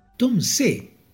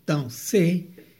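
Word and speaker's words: Tom Zé: Tom C, que es una pronunciación portuguesa, Tom C, fue nacido como Antonio José Santana Martins el 11 de octubre de 1936 en Irará, Bahía, Brasil.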